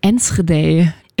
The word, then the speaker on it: Enschede: In Enschede. Im Osten Holland. – Du hast das direkt so niederländisch ausgesprochen.